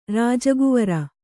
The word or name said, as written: ರಾಜಕುವರ